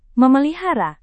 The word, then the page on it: memelihara: Indonesian